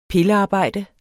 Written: pillearbejde

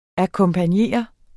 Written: akkompagnere

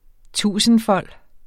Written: tusindfold

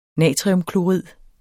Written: natriumklorid

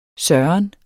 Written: søren

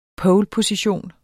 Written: poleposition

